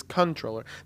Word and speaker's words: comptroller: Well, this is stupid because it's spelled C-O-M-P-T, troller, you know? – Yeah. – And so you pronounce it the way it's spelled, and they want you to still call it controller. The correct way is controller.